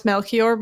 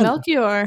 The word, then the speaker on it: Melchior: I only know how to pronounce Melchior because it's a name in my favorite musical, Spring Awakening.